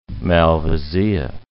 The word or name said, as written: malvasia